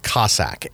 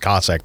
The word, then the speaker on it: Cosaque: But one thing I did want to mention is that black oat, it's called Cossack, and it's not spelled like the Russian thing. It's Cosaque. So whenever you see that weird, you know, spelling there, it's just pronounced Cossack.